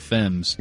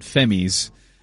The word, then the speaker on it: Femmes: wrote down the pronunciation for Violent Femmes as Violent Femmies.